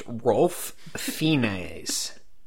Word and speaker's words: Ralph Fiennes: It's Ralph Fiennes. The L is silent apparently, and that drives everyone, including myself, crazy. – Ralph Fiennes. – It's pronounced Rolf Fiennes.